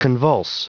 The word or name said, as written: convulse